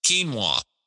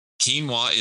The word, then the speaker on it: quinoa: Quinoa. If you've never heard of it, you should have. Quinoa is spelled Q-U-I-N-O-A.